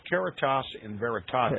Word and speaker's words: Caritas in Veritate: It's pronounced Caritas in Veritate, okay, which means charity and truth.